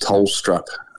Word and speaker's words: Tholstrup: So, now we're excited by both the selections of Caleb and, and Koltyn, which is pronounced Tholstrup um, as a Danish background, yeah.